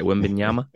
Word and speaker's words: Wembanyama: And also what can happen in, you know, this upcoming draft for Wembanyama? – Wembanyama sweepstakes. Yep. I'm going to say that right. Wembanyama.